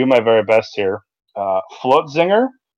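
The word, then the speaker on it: Flötzinger: It's got the umlaut over the O, and I always sucked at that part. F L O umlaut T Z I N G E R. Flotzinger.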